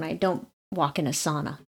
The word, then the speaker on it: sauna: sauna is pronounced sauna, and you will be looked at funny.